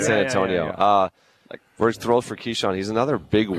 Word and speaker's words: Keyshawn: Is it Keyshawn? Is that how you say his name? – Yeah, Keyshawn George.